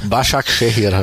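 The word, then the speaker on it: Başakşehir: Ähm, wir schauen zum äh, Basak Kejir Football Kulübübü. – Basak Shehir heißt glaube ich.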